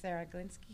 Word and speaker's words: Sarah Glinski: We have the wonderful Sarah Glinski here. Am I pronouncing it right? – Yes, that's perfect. – Sarah Glinski, I pronounce it wonderfully.